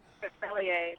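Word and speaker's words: Grospellier: Is it Grosspelier? – Grosspelier, yeah.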